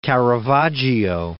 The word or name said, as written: Caravaggio